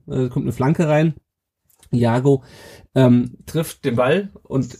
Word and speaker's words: Iago: Äh, und, ähm, wo wir gerade den Vorlagengeber oder den denjenigen äh, erwähnt haben, der das Tor eingeleitet hat, nämlich Iago, heißt wird er ausgesprochen, ne? oder Iago? Ich hab, mir ist das Spiel, Iago, ja.